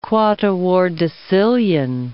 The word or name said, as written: quattuordecillion